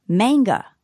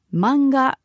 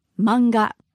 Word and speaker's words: manga: In English, it might be pronounced manga, but in Japanese, we don't have that strong a sound. So it's simply manga, manga, to mean comic book.